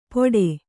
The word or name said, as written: ಪೊಡೆ